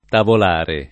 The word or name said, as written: tavolare